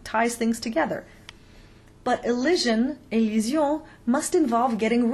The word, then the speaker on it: élision: So just to recap the important points, liaison is not the same thing as elision, élision.